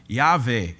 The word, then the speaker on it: Yahweh: So it's YHWH, but the W, like in the German language, is pronounced with a V. Yahweh is the way it would be pronounced, or Jehovah.